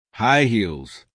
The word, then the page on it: high heels: American English